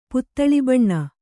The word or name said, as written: ಪುತ್ತಳಿಬಣ್ಣ